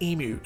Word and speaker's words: emu: For those of you who don't live in Australia, maybe you're American, and by the way, America, it's pronounced emu, not emu, okay? It's not an emu, it's an emu.